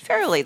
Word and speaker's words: Farrelly: Farrelly, that's a kind of easy Farrelly.